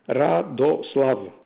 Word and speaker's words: Radoslav: Radoslav, that's the first name.